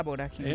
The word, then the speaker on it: vorágine: Y en ese vorágino, ¿cómo le llaman? – En esa vorágine. En esa vorágine. Vorágine.